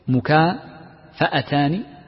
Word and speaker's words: مكافأتان: في نطقها هناك نطقان، إما بفتح الفاء أو بكسرها، مكافأتان أو مكافئتان، وكلا النطقين صحيح، ولكن المحدثين ينطقونها بالفتح ويختارون ذلك.